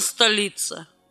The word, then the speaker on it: Столица: Должен заметить, что немалая часть исследователей жизни и творчества поэтессы настаивает на том, что в фамилии, доставшейся ей от мужа, орфоэпически ставить ударение, вернее, на первый слог, но опираясь на известное есенинское четверостишие «Любовь столица, любовь столица, о ком я думал, о ком гадал. Она как демон, она как львица, но лик невинен и зорно ал». Все же будем ставить ударение на слог второй.